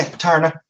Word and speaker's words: Taarna: Did I pronounce it right? – Yeah, Taarna. – Taarna. Oh, it's just A, no I.